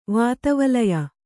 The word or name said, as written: ವಾತವಲಯ